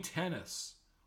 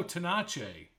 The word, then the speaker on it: Tenace: Gene Tennis, also known as Gino Tenace, if you want to pronounce it in Italian, I guess, who had only 5 home runs in the regular season, hit 4 in the series, and was named MVP.